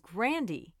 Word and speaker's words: Grande: And Ariana Grande's real last name is Grandy.